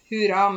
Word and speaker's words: hurra: hora på 17. mai. Så det er hurra med u og dobbel r. Hurra.